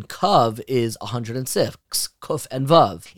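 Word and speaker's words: kav (קו): And if you take the word for circumference in this pasuk, which is kaveh, that's how it's spelled, but it's pronounced kav without a hey, and you take the gematria of those two words, look it up in the pasuk.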